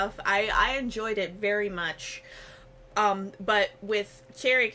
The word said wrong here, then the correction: Cherie: Curry, am I saying her name right? Cherry Curry? – Sherry Curry.